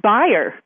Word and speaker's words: Bayer: My name is German, and it's pronounced Bayer.